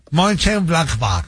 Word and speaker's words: Mönchengladbach: Del Mönchengladbach, así exacto, así como lo pronunciaría, así como lo pronuncia, así es. Así va el alemán. – Mönchengladbach.